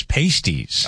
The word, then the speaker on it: pasties: I mispronounced that. It wasn't pastries. It was pasties.